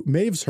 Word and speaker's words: Medb: And Queen Maeve, which is spelled M E D B, for those of you who are wondering.